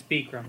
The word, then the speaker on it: Bikram: Greg, isn't it pronounced Bikram? – It's Bikram.